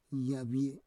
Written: yavie

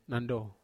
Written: nando

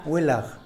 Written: welaj

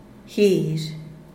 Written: guizh

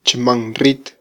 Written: chmanrit

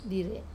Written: dir

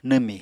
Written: nwmi

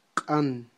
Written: q'an

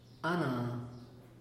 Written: ana

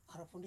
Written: Arafundi

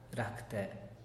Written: racte

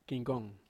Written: kangkong

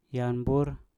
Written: yam bur

